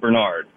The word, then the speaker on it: Bernard: My actual name is spelled Bernard, but I pronounce it Bernard.